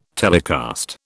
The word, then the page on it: telecast: British English